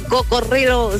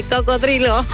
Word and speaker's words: cocodrilo: No se dice cocorrilo, se dice cocodrilo.